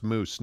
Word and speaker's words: Moes: Joshua Moose, M-O-E-S, says, my name is pronounced Moose, not joking.